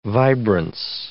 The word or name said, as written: vibrance